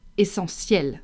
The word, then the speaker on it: essentiel: Take the word essentiel, for example, which is the word essential.